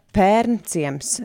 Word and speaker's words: Pērnciems: Jā, jā, protams, ka to vietu nesauc eh, pērne, bet ir pē, pērnciems. Es ceru, ka ar pareizu izrunu, pareizu e-burtu izrunu.